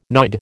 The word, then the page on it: nod: American English